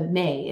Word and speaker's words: me: gifted the the me the me is the may is what it's called it's spelled me mm-hmm. but the may from her, her grandfather enki which are the sacred cultures of the land the spiritual powers of the land but mm-hmm.